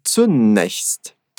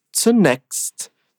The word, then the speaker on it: zunächst: O meu sotaque, a minha escolha, digamos, né, de pronúncia, é falar zunächst, zunächst, com um chiadinho. Mas em algumas regiões da Alemanha, você vai ouvir falar zunächst, zunächst, como se fosse um som de KS ao invés do som chiado. E as duas pronúncias estão corretas, ok?